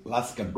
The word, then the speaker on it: Luscombe: Luscombe.